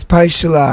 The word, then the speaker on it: Paixalá: It's Paixalá. I think that's how it's pronounced. It's P-A-I-X-A-L-A. So. – Yep, and he said it in the thing, so it's Paixalá.